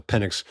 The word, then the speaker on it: Penix: That was not the case on New Year's Day, because Washington, Texas was also a barn burner, particularly Michael Penix Jr., who has heard all of your jokes.